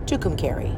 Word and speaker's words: Tucumcari: And we have stopped before near and about Tucumcari, New Mexico. Would you want to say that three times quickly for me before I proceed? Tucumcari.